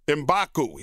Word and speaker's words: Mbaku: Mbaku.